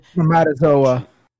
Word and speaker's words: spermatozoa: spermatosa and leading them... – Spermatozoa. – Is that how you say it? – Spermatozoa, yep.